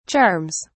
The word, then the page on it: charms: British English